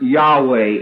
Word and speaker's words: Yahweh: The name of God is pronounced Yahweh in Hebrew.